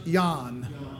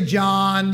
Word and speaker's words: Jan: Number two, Jan Hus, H-U-S-S. Jan Hus. It should be pronounced Jan. Yeah, but you can say John, you can say Jan. You can say whatever.